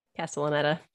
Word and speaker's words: Castellaneta: So then Dan Castaneda, is that how you say his name? – Castellaneta did the voice for that.